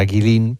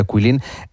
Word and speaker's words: Guilin: Bueno, esta ciudad de Cuilín, por si alguien la busca en internet, se escribe G-U-I-L-I-N, como si fuera Guilín, pero se pronuncia Cuilín.